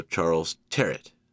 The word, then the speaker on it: Charles Tyrwhitt: Now, Charles Tyrwhitt that's how I choose to pronounce it, but I have heard it's also Charles Terrett.